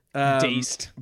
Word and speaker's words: Dazed: It's either Dazed or Daste. It looks like Taste just spelled with a D. – Dazed.